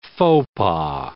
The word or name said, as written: faux pas